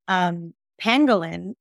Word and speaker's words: pangolin: So it's funny because most people don't know, and they think I mispronounced and said I was trying to say penguin. Pangolin is a little armadillo style creature that has scales the back.